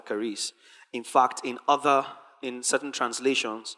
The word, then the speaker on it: charis: We want to take off from looking at what grace is, on what the word grace, charis, means. It's actually spelled charis, but it's pronounced charis, C-H-A-R-I-S. Some people spell it phonetically, so K-A-R-I-S. They spell it how it is pronounced.